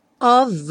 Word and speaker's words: of: I'm dreaming of a. Esse of, já vimos muitas vezes, o F vai ter som de V, e aqui juntando com a.